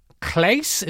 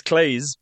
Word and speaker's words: Claes: We put up Claes, is that how you pronounce that? – I think it's Claes, but yeah, go on.